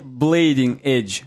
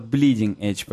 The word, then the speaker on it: bleeding edge: И Blading edge. Вот bleeding edge, правильно говорить.